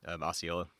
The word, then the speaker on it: Osceola: There's a different pronunciation and stuff, but that's how most people know it. Osceola.